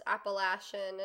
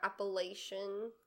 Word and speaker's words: Appalachian: I also didn't realize until recently that it's pronounced Appalachian and not Appalachian.